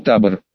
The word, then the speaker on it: табор: Утром к нам приехал табор, который с ударением на первый слог, цыганский.